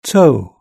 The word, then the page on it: toe: British English